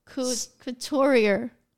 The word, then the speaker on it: couturier: Wait, wait, sorry, what? Couturier. couturier or Pretoria? – Cut C. Couturier. – Because Pretoria is Roman. – No, couturier.